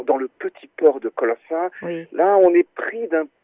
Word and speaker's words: Colonsay: Et voilà, c'est comme ça que je pense que j'ai découvert, par exemple, Colossa, ça s'écrit C-O-L-O-N-S-A-Y, oui, oui. – Mais les habitants Colense. De l'île prononcent Colossa.